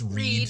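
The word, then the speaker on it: Wrede: I believe it's how it's pronounced, W-R-E-D-E. I'm guessing it's Reed, silent W.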